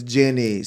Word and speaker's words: Ginny: So it's Ginny. Sorry.